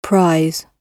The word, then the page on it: anniversary: /ˌæn.ɪˈvɜː.sər.i/; British English